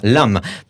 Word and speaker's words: Lamb: En fait, c'est le film Lamb, euh, enfin Lamb, pour les, parce que j'ai une formation d'angliciste, on, ne prononce pas le B.